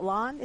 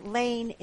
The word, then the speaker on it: Lahn: You mentioned that the guy that Zach Lahn, is it Lahn? Is that how it's, well, it looks like Lahn. It's pronounced Lane. – Okay. – It's L-A-H-N, but it's pronounced like Lane.